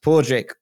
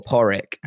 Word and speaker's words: Pádraig: It can be pronounced Pádraig or Porrick, and it's P-A-D-R-A-I-G. And I remember there was a famous golfer called Porrick Harrington, and his name was butchered by everyone under the sun.